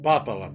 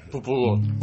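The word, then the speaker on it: popolo: То есть, будем петь «Аванти Попола», будем петь именно «Аванти Попола», а не «Аванти Папола», «Попола». – «Популо».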